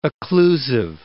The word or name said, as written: occlusive